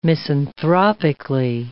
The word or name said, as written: misanthropically